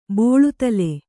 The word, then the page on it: ಬೋಳುದಲೆ: bōḷudale